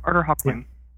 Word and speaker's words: Arthur Hawkwing: Yep, Arthur Hawkwing.